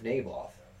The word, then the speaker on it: Naboth: N-A-B-O-T-H.